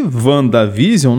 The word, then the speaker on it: WandaVision: Inclusive até eles falam WandaVision, que é segundo a pronúncia correta do nome lá fora. Pode ser então que WandaVision não represente Wanda e Visão, mas sim a visão da Wanda sobre o assunto, o que deixa tudo ainda mais misterioso.